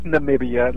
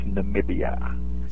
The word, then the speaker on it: Namibia: It's Namibia. A lot of people mispronounce it, and you know, I've just been there so much. It's Namibia.